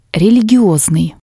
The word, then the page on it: religiosnii: Russian